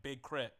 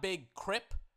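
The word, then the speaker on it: Big K.R.I.T.: Not Big Crip, as in like a Crip, as in sea walking. Big Crit, as in critical energy, as in like critical. But it's spelled K-R-I-T.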